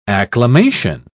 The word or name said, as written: acclamation